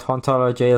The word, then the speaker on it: hauntology: I just want to add to my favorite thing about hauntology is that it's like a pun that only works in French. Because they don't pronounce the H, so it sounds the same as ontology.